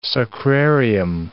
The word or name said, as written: sacrarium